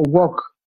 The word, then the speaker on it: Wog: And it would always come out Woggy or Wog. So I've been called Wog ever since then, W-O-G.